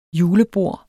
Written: julebord

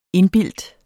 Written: indbildt

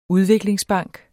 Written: udviklingsbank

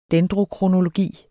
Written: dendrokronologi

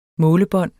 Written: målebånd

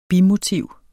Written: bimotiv

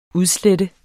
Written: UDSLETTES

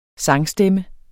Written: sangstemme

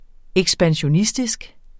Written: ekspansionistisk

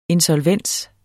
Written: insolvens

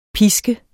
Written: pisk

